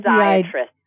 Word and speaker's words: physiatrist: Psych- yes, physiatrist.